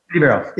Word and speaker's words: ANDIVERO: ANDIVERO, yeah.